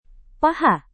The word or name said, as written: paha